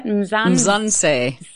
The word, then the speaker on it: Mzansi: Mzansi.